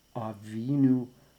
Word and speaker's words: Avinu: I think it helps emphasize the penitential nature of this section by putting just a tiny little bit of pressure on the second syllable of those three words. Chatanu, Avinu, Pashanu.